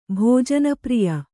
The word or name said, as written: ಭೋಜನಪ್ರಿಯ